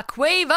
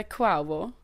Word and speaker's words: Quavo: ja, han uttalade det i själv i låten bara Quavo. Aha, jag brukar säga Quavo. Nej, det är fel.